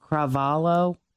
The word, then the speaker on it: Cravalho: Cravalho? – Yeah.